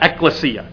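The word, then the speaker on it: ekklesia (ἐκκλησία): I have no idea how to, you know, really read that, but that's the Greek word there, that in English you pronounce that ekklesia.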